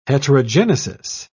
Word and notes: heterogenesis: British English

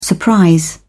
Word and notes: surprise: British English

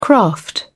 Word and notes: craft: British English

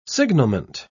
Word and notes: signalment: British English

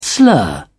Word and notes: slur: British English

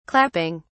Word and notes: clapping: British English

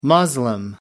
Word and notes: muslim: American English

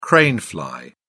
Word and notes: crane fly: British English

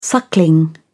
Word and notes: suckling: British English

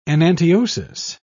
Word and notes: enantiosis: British English

